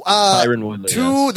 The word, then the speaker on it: Tyron Woodley: Tyron Woodley.